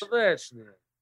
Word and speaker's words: Stolichnaya: Pronounce this one more time. – Solichnaya. – Solichnaya. – Stolichnaya. Niche. – Stolichnaya. – There you go. You're a regular Ruski now.